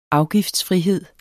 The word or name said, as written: afgiftsfrihed